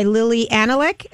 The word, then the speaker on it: Lili Anolik: by Lili Anolik.